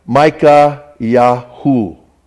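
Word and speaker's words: Mikhayahu: Micah, his name in the original language would have been pronounced different than we pronounce it here. In our Bibles. In the original language, it would have been Micah Yah or Micah Yahu.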